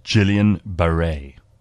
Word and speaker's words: Guillain-Barré: Apparently, it's pronounced Guillain-Barré. Sorry.